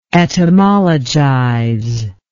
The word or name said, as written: etymologizable